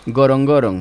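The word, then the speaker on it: Ngorongoro: Gorongoron.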